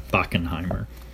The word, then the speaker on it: Bachenheimer: That's spelled B-A-C-H-E-N-H-E-I-M-E-R, Bakkenheimer.